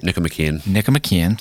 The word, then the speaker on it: Nicomachean: So here's here's something that Lutz just comments about, according to Aristotle's Nicomachean Ethics, if I pronounce that right. – Nicomachean, thank you.